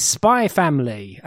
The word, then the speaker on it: Spy x Family: But it's Spy Family, and it's one of these series that has an X in the middle, but I am given to be told that the X is silent, so it reads a Spy X Family, but that just sounds even sillier now I said it out loud.